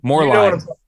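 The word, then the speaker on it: Moerlein: The Merlin. I don't think I'm saying it right. But Moreline. – Moreline. Yeah. Thank you. Moreline.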